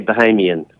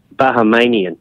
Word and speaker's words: Bahamian: The locals will usually say Bahamian. Bahamian or... As I heard U.S. Senator say, Bahamanian, Are you a U.S.